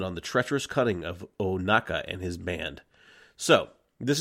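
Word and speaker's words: Ohnaka: Sorry, I butchered that. Hondo Onaka.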